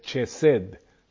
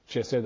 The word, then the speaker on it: Chesed: porque la palabra se escribe en español como con C, comenzando con C, Chesed, Chesed, así como suena.